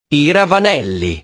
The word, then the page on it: i ravanelli: italiano